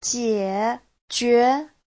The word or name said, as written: jie3jue2